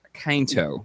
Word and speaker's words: Quinto: I think it's Zachary Quinto. – No, it pretty is not. Sure it's... Yeah, the emphasis is on the I.